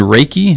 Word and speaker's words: Reiki: Self-reflection, coaching, and Reiki.